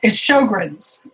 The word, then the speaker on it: Sjögren's: And I always feel like I'm mispronouncing this one, but is it called Sjögren's syndrome? – It's Sjögren's is how Sjögren's. You pronounce it. Sjögren's, yeah.